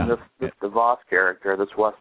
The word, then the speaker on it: DeVos: DeVos. DeVos. – Devos. – Yep. – All right. Like Davos. Davos.